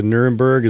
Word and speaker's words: Nuremberg: We're just going to ignore completely the Nuremberg.